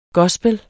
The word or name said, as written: gospel